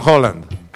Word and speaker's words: Haaland: El mismísimo actor de Spider-Man, Tom Holland, le mandó un mensaje al vikingo mayor, Erling Haaland, que en noruego se pronuncia Haaland, también como el de Tom.